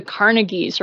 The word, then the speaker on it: Carnegie: I went to the Carnegies or Carnegie. It depends on where in the country you are as to how you pronounce that.